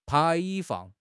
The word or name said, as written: 排放